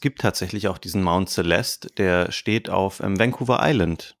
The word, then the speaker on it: Celeste: Wie spricht man es eigentlich aus? Lass uns erstmal einigen. Celeste, würde ich sagen. – Celeste, oder? Würde ich auch sagen. Also das E hinten ist stumm.